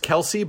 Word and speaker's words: Qellessi: Instagram is, it's pronounced Kelsey, but it's spelled It's the at, and then QELLESSI.